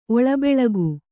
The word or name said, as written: ಒಳಬೆಳಕು